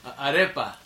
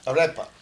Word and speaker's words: arepa: Arepa. – Arepa.